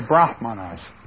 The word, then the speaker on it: Brahman: The Brahmins are the priests, and they are related to Brahman, B-R-A-H-M-A-N, without a long A.